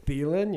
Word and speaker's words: Thielen: Thielen, you dumbass.